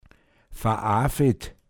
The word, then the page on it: vabandld: Pinzgauer Mundart